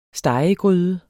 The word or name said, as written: stegegryde